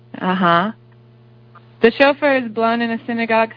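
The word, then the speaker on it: shofar: So, S-H-O-F-A-R. A chauffeur, Hebrew, pronounced, I don't know how to pronounce that. Can you say the Hebrew? – Chauffeur. Chauffeur.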